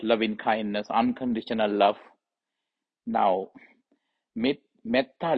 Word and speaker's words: Metta: Now, Metta Paramita is often understood with another term, M-E-T-T-A, Metta, T-T-A, long A, dash, H A V I H A long A R A Vihar.